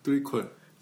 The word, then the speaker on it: threequel: 3이라고 읽어야 되죠? 셋 아니죠? – 트리콜 아니에요? – 쓰리? – 트리콜. – 트리콜. 어, 맞아요. 아, 예, 맞아요. 좋은 지적이시네요. 트리콜.